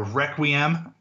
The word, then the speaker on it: Requiem: They on their website, I'm looking at it right now, they have the cut for Requiem, which is how they pronounce it in the song, being the Latin Catholic schoolboy that I am, I always want to say Requiem, but man, I listened to it today and Lauren Dreyfus is phenomenal.